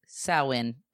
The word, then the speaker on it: Samhain: Halloween originated from the ancient Celtic festival known as Samhain, which is pronounced Samhain. So, okay.